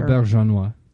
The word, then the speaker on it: Auberjonois: Auberjonois. – Thank you for saying it better.